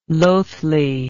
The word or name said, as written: lothly